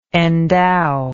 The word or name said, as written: endower